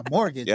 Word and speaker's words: mortgage: I got a mortgage. – Yeah, I got a mortgage. – I got a mortgage with a silent T.